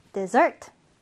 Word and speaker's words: dessert: 자, 이 디저트라는 단어 있잖아요. 강세 여러분 주의해서 알아두세요. 자, 따라해볼까요? 뒤에 강세가 있습니다. dessert, dessert가 되겠습니다.